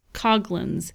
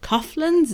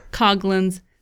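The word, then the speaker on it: Coghlan's: It's C-O-G-H-L-A-N-S, Coglins. So they're like, people ask us all the time, is it Coughlins? Is it Coglins? Is it? They've heard it all. But it's Coglins. So just take the H out, and that's how you pronounce it.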